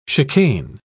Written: chicane

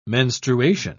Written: menstruation

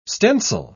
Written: stencil

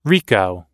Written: rico

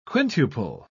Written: quintuple

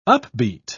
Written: upbeat

